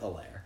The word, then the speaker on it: Helaire: I'm going with Helaire. I think it's a good in between. – You took French, so you would. – Yeah, that's exactly how a French person would pronounce it.